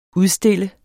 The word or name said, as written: udstille